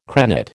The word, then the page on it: crenate: American English